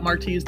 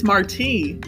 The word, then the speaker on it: Marty: Dad was funny about Marty's name, always making sure people knew how to pronounce it properly. It's Marty, like Jose Marti, the Cuban poet, not Marty, like Marty McFly from Back to the Future.